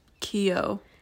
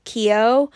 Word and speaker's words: Keough: I think it's Keough. – Keough.